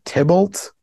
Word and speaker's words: Tybalt: You have Tom Kenny as Geppetto, Pauly Shore as Pinocchio, and John here is I was reading it as Tybalt, but it's Tybalt. – It's I don't know. T-I-Bolt. – It's spelled like T-Y-B-A-L-T.